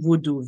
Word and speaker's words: Vodou: So the spiritual tradition in Haiti is pronounced as Haitian voodoo, V-O-D-O-U, voodoo. However, the origin and foundation of that tradition is out of West Africa, Benin, and it's called voodoon.